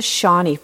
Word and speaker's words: Seany: He is a La Mesa resident who's the Vice President of Development at the Shawnee Foundation, and that's spelled S-E-A-N-Y Foundation, which is devoted to helping children and young people with childhood cancers.